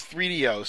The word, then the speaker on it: 3DO: And that's how you're supposed to pronounce it, is 3DO.